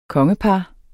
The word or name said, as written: kongepar